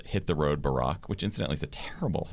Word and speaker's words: Barack: No, it's hit, because in British it's Hit the Road, Barack, and so it rhymes with Jack, exactly.